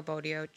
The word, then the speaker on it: Botticelli: Like, this is the painting by Sandro Botticelli. Mm-hmm. Sorry for my pronunciation. – I think you did wonderful.